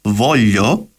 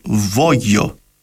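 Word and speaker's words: voglio: το ρήμα θέλω προφέρεται βόλιο στα επίσημα ιταλικά, αλλά σε κάποιες περιοχές προφέρουν το μεγεϊσμό ως βόγιο.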